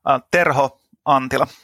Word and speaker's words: Terho Antila: Terho Antila. – Terho Antila. – Yes, that's nice. – Pretty good. – Nice. – The R is kind of hard for English-speaking people, yeah.